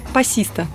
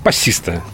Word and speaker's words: пассиста: Пассиста. – Пассиста.